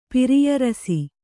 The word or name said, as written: ಪಿರಿಯರಸಿ